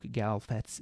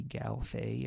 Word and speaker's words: Galfet: So that's kind of, that's the inspiration for Duke Galfet's name here. I guess it probably should be Galfay if I was sticking to the French pronunciation, but I went with Galfet for the book here.